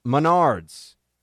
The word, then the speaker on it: Menards: you always pronounce incorrectly. It's pronounced Menards, and you can hear their jingle at the link below. Oh, man, I've been saying it wrong.